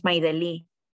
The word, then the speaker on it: Maydelli: And I said, oh, I think that's me, but that's not how you say my name. My name is Maydelli.